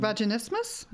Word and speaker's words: vaginismus: Vaginismus? – Vaginismus, that's the one.